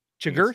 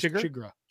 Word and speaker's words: Chigurh: How do you say his name again? Chigurh? – I think it's Chigurh. – Chigurh.